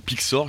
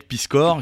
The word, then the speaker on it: Piskor: Alors Hip Hop Family Tree, c'est un projet du dessinateur Ed Piskor ou Pixor. Je voudrais pas quand même non, c'est ça, Ed Piskor, je ne voudrais pas écorcher son nom.